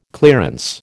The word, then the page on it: clearance: American English